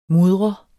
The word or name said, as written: mudret